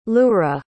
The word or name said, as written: lura